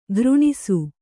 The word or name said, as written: ಖುರಣಸ